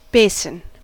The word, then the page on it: basin: American English